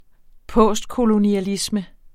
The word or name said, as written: postkolonialisme